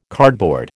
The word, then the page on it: cardboard: American English